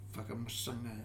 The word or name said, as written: Faka Masaga